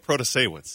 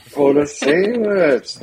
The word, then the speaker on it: Protasiewicz: Janet Protasiewicz. – Thank you. – Protasiewicz.